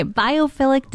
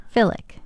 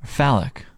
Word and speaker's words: biophilic: Biophilic design. – Pardon? – Biophilic design. – Philic? – Philic. – Phallic. – Philic, not phallic.